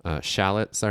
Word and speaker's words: shallot: Shallots are another. Onions. – Shallot, if you're British. – Is that really how they say it? – Yes.